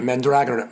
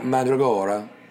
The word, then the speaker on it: Mandragora: Also, he pronounces it Mandragora, Mandragora, not Mandragora. Just letting y'all know.